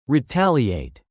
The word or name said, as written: retaliate